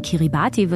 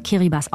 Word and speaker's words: Kiribati: Das ist eine Inselgruppe, die schreibt man Kiribati, wird aber Kiribati ausgesprochen.